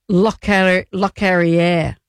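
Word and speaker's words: Locorriere: His mate Lockery, he has passed away. Let's say his name correctly, she said, looking for the piece of paper. Lockerier.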